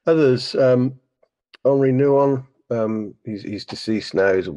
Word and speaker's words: Henri Nouwen: It's spelled Henry, but it's Henri Nouwen.